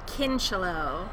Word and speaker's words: Kincheloe: Jennifer Kincheloe.